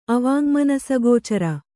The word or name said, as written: ಅವಾಙ್ಮನಸಗೋಚರ